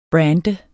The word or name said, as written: brander